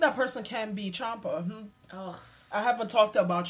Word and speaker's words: Ciampa: Yeah, it's Italian. The C-I makes a C-H sound, so it's Ciampa.